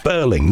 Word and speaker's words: Birling: I'd forgotten that it's spelled B-I-R-L-I-N-G, Burling, not as in Burlington.